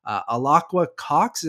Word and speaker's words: Alaqua Cox: Also starring Vera Farmiga, Fra Fee, Tony Dalton, Brian D'Arcy James, Alaqua Cox. Am I pronouncing that right, Eric? I think so. – Yes.